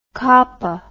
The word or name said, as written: capa